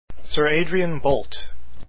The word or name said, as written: boult